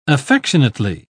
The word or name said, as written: affectionately